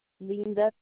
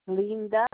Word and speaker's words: Linda: Linda Tanner. – Spell it, please. – Linda, L-I-N-D-A.